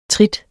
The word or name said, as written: TRITTETS